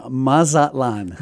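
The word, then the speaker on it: Mazatlán: Okay, we are in Mazatlan.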